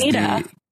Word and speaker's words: Neda: Adam Rail is Nita. Mike Nita, N-E-D-A. – Nita? – Yeah. It's spelled like Netta, but it's pronounced Nita.